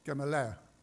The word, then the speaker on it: Gamaliel: How do you pronounce it? Gamaliel.